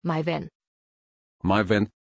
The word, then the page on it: Maiwenn: German